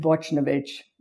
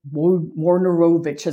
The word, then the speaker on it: Wojnarowicz: I'm going to butcher his name, David Wojnarowicz, or Wojnarowicz, as I always pronounce it.